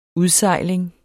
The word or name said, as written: udsejling